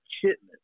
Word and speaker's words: chitlins: They don't say chitterlings. They say chitlins. – They say chitlings, not even chitterling. – They say chitlins. Chitlins. That's how they pronounce it. Chitlins. Now, it might have originally been chitlings, and they just saying it real fast and turned into chitlins.